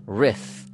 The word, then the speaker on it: Ryf: Now, the fastest Ironman World Championship course record by a woman is Daniela Ryf. I believe that's how you pronounce her name. R-Y-F.